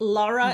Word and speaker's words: Lara: And so Gail, Lara, right? Lara is how you pronounce it? Perfect.